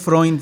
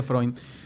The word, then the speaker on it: Freund: Mi, apellido es Freund, eh, se, pronuncia Freund con una N, la diferencia con Sigmund Freund, eh, significa amigo en alemán, Freund.